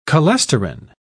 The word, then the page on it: cholesterin: British English